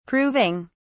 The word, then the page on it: proving: British English